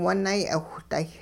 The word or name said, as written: wunay'iwhday